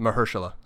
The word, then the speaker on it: Mahershala: Mahershala, I'm probably butchering. How do you pronounce it? – Mahershala. Sorry. Thank you.